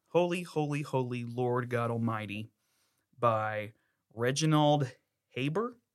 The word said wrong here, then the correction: Heber: Heber? Heber. I would say Heber.